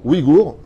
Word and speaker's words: Ouïghours: D'après donc les Uyghurs, comme ça qu'on prononce Ouïghours, autant pour moi, d'accord euh, Basés à Washington.